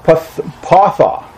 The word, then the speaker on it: pathah: It's the word patha. It's spelled P-A-T-H-A-H, okay, but it's pronounced patha.